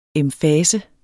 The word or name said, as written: emfasen